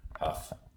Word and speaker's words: Hough: So that's why it's spelled the way it is, with the H-O-U-G-H, Huff.